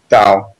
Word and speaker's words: Tao: Tao. That's why it doesn't. That's why it has a T, but it sounds like a D.